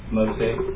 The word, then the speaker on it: Moseh: Mosey. – Mosey, spell that for me, Mosey. – M-O-S-E-H.